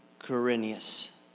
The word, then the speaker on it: Quirinius: And I always like to have someone else read this because I can't pronounce Quirinius. Is that close enough? All right, good. That's correct.